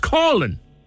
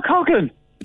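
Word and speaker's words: Coughlan: You've mispronounced Nicola's name. It's Colin. – Isn't that Coughlin? – It's Colin. You're in Cork now. – Yes, when you talk to us, there's no such thing as Coughlin in Cork.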